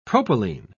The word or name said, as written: propylene